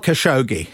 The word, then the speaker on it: Khashoggi: Well, Jamal Khashoggi.